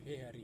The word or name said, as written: pehari